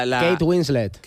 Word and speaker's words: Kate Winslet: Kate Winslet.